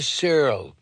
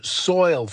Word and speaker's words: soil: the assistant principal, may she rot in the Searle. That's how she pronounced it, while I cringed. The worm is good for the Searle. It's soil, fatso, I wanted to say, and then shovel her under it.